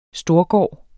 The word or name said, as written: storgård